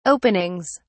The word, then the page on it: openings: British English